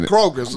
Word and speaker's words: Kroger: Like, okay, I'm shopping at Kroger's. – They got this. – I ain't never. – Did you know it's Kroger? There's no S in Kroger.